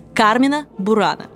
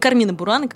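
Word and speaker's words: Carmina Burana: Так вот, правильно произносить следует «Кармина Бурана», а не «Кармина Бурана», как это делают сегодня очень многие.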